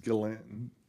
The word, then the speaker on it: Ghislaine: Yeah, I think it's pronounced Ghislaine.